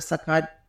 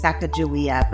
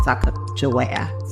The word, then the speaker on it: Sacagawea: I learned that name is Sacagawea, but I know that's not how it should be pronounced. I believe it's Sacagawea. Sacagawea.